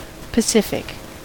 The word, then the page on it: pacific: American English